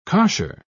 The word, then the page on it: cosher: British English